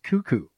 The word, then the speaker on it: Koekkoek: Slater Cuckoo, spelled Koekkoek, but pronounced Cuckoo.